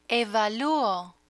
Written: evaluo

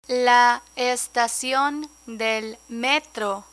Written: la estacion del metro

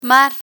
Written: mar

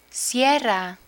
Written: sierra

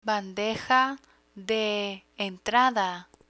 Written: bandeja de entrada

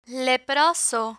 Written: leproso

